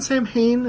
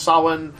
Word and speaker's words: Samhain: for people cringing right now, would be more actually pronounced Samhain. But yes, every American in existence has mispronounced it as Sam Hain, and so they went ahead and said, like, that's our character, Sam.